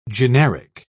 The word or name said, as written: generic